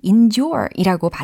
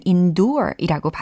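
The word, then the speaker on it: endure: 어, 참고로 이 endure이라고 발음을 하셔도 되고 아니면 endure이라고 발음을 하셔도 됩니다. 미국식으로는 endure이라고 주로 많이 하고요. 영국식으로는 endure이라고 많이 발음을 합니다.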